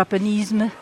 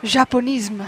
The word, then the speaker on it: Japonisme: Is that how you say it? Japonisme? Japonism? – Well, it's a French word, yeah. So Japonisme. – Japonisme.